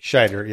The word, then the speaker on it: Scheider: I'm probably not pronouncing that right. – Scheider, yeah. – And I always want to say Rob Schneider, but it's Roy Scheider.